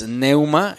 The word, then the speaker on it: pneuma: El espíritu, esta palabra espíritu viene del griego pneuma, que es P de papá, N de niño, E, U, M, A, pneuma, con acento en la U.